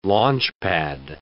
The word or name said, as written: launchpad